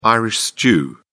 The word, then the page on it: irish stew: British English